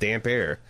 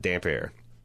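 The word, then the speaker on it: Damphair: So he's got damp hair. But that's how you pronounce it, damp air.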